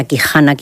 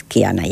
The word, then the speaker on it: Kihana: Entonces, la H muda, aquí todo el mundo decía Kihana. Yo también decía Kihana muchas veces, en lugar de decir Kihana, que es un poco más complicado. Bueno, el caso es que la H muda y es Kiana ya.